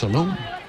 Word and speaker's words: Salom: Looks like Solomon. If you go to the website and you click on the ear, each player pronounces the proper pronunciation of their name. It's Salom for a piece.